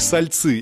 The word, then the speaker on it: Сольцы: Впервые слыша это название, многие путаются с ударением – сальцы или сольцы. Все-таки название этого городка в Новгородской области звучит как сальцы, и этим именем город обязан многочисленным соленым источникам вокруг реки Шелони.